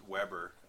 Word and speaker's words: Weber: I'm glad I'm a centerpiece, and I will eventually learn my last name, Carrie, but right now I just pronounce it Weber. Interestingly enough, most of the rest of the world kind of says Weber. It's really only the Kitchener area that says Weber.